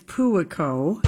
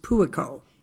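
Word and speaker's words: Puakō: It's Puaco. – Puaco. How do you spell it? I literally was looking it up today. – Okay, Puako, and it's pronounced Puaco.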